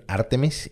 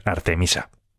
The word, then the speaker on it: Artemis: Eh, esto, si quieres, nos ponemos de acuerdo y a partir de ahora pronunciamos siempre bien Artemis y a lo mejor, pues, marcamos tendencia y todo el mundo empieza a pronunciar. Yo creo que deberíamos decir Artemisa.